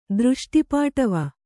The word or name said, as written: ದೃಷ್ಟಿಪಾಟವ